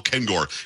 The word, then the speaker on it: Kengor: And by the way, I told you before we started, and again, let me make sure you get the name right. It's Dr. Paul Kengor, K-E-N-G-O-R.